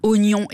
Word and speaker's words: oignon: Et puis, oignon écrit O-I-G-N-O-N, à plat de couture, la proposition plus conforme à la prononciation O-G-N-O-N sans I.